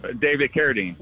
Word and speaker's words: Carradine: I think more of the challenge is, how do you pronounce David's name? – David Carradine. – Carradine. Okay, thank you. I stand corrected.